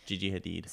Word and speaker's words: Gigi Hadid: Yeah, did I not say it right? – Yeah, it's Gigi Hadid.